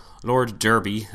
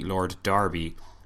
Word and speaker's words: Derby: Lord Derby, as I've been pronouncing it, is actually Lord Darby, and will be referring to him as such from now on, just so you know.